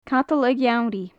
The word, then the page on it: cadal a gheamhraidh: gaelic